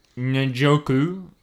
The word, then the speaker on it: Njoku: They had that good young tight end in David Njoku.